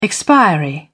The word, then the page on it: expiry: British English